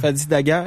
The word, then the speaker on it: Dagher: Et un des témoignages les plus touchants, c'est le chef du SPVM, Fadi Dagher, on le prononce-tu comme ça, Dagher? – Fadi Dagher. – Dagher, Dagher. J'ai sorti Dagher.